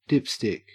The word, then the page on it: dipstick: Australian English